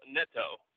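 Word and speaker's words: Neto: And then Raul Nito, who is going to kind of be the – is it Nato? I already messed up the pronunciation, didn't I? – I love it. It's my favorite thing about you. So it is technically Raul Nito.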